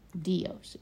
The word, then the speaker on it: Dios: Dios is pronounced.